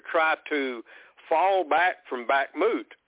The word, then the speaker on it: Bakhmut: The Russians have encircled Bakhmut, I guess. Is that how you pronounce that city? – Yeah, Bakhmut.